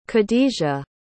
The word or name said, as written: khadejah